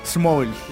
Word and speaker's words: small: Small. – Es que no es una ES. No es como small. Esmalte ya. No, no. Sí. Small. Recordemos que la S al principio suena como un ceseo.